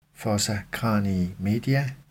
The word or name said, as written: fossa cranii media